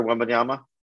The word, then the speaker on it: Wembanyama: Victor Wembanyama? – Yeah.